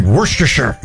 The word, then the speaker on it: Worcestershire: Worcestershire. – It's like Worcestershire. You have to slur it together like you've been drinking.